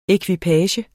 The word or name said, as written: EKVIPAGEN